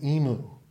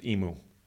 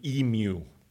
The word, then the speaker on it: Emu: And by the way, I got a comment that I mispronounced Emu. It's supposed to be Emu. – Emu. – From a fellow from Australia told me this.